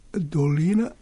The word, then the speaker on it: dolina: A dolina treba izgovoriti ravno, a ne da, s akcentom na prvom delu.